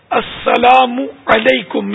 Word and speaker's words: السلام علیکم: صحیح تلفظ ہے السلام علیکم میم پر پیش ہے میم کے بعد واو نہیں ہے